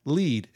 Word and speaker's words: Lead: nearby is a city called Lead. It actually shows up as lead, but it's actually pronounced Lead.